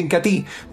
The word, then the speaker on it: Incatea: Se escribe Incatea, pero se pronuncia Incatí.